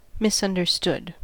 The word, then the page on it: misunderstood: American English